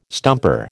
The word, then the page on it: stumper: American English